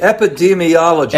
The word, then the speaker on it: epidemiologist: Well, everybody is asking this question, and what the epidemi- uh, epi- I don't know how to pronounce this. Uh, the epidemiologen. Ep uh, epidemi yeah. – Yes, those guys. Uh, yeah, those okay. the most important guys right now. What they say. Epidemiologist. Epidemiologist. Okay.